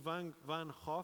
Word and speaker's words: Van Gogh: So Van Gogh, as it's actually pronounced, was deeply, deeply disappointed because he was utterly convicted that that's what he should be doing.